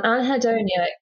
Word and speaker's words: anhedonia: So it's said as it's spelled, which is handy. And anhedonia is the loss of enjoyment in things that you used to love or finding it really, really hard to feel joy.